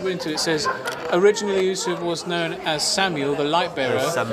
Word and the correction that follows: Samael: Samael, the light bearer, though he eventually became better known as Lucifer. So Samuel, who's Samuel? – Samael. – I keep saying it wrong, Samael.